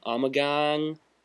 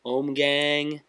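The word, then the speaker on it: Ommegang: Yeah, this is, I'm not sure how to pronounce it, but it's O-M-M-E-G-A-N-G, Omegang, Omegang.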